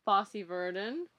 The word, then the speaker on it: Fosse/Verdon: But yes, Yvette has been seen in Fosse, Verdon. Is that how it's pronounced? – Fosse, Verdon. Yes.